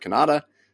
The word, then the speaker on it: Cannata: I'm at Jeff Cannata, spelled with two N's and one T.